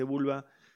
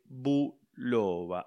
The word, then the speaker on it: Bulova: No se dice vulva, se dice buloba.